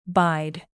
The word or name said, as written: bide